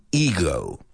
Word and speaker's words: Ego: He was in Guardians of the Galaxy 2, where he portrayed Ego.